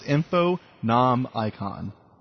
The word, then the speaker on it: Infonomicon: That's I-N-F-O-N-O-M-I-C-O-N, just like it's pronounced, Infonomicon.